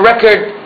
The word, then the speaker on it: record: Because the words are pronounced differently. Records, it's a verb. Record is a noun.